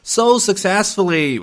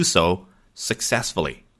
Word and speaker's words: successfully: 咱们中国呢，成功的着陆，所以是 so successfully 啊，所以这个地方千万不要念成 so successfully 啊，如此成功的去做，而是 becoming the second country to do so successfully。